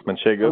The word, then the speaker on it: manchego: a not a, a gouda, but a you know a different kind of manchega or um, manchego.